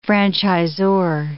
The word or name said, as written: franchisor